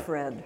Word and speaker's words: Offred: Okay, Offred.